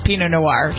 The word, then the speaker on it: Pinot Noir: From the heart of Australia, Pinot Noir. Is that how you pronounce that, Pinot Noir? – Cabernet Sauvignon and Pinot Noir, sure.